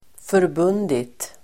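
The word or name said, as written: förbundit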